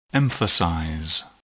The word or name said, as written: emphasize